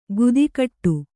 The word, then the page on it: ಗುದಿಕಟ್ಟು: gudikaṭṭu